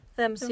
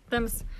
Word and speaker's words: Thames: Und zwar äh, wird es Thems ausgesprochen? Thems, ja. Thems. Oh Gott, dieses Doppel-TH.